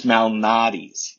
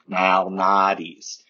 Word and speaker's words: Malnati's: It's Malnati's. You have to channel your inner Chicagoan for the A's. Malnati's.